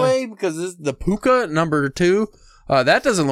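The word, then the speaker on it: púca: Uh, cause in Ireland, in uh, Gaelic, all C's are all K's pronounced, so it would be, still be Puka.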